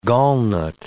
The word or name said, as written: gallnut